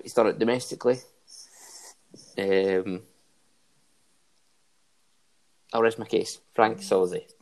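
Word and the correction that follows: Sauzée: Swazzy, was it? – Swazzy, you're right. Been saying his name wrong the whole time.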